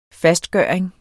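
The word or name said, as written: FASTGØRING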